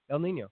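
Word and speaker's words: El Niño: El Nino. No, that was good. – El Nino, yeah. All right, cool. – Three syllables. You did a good job.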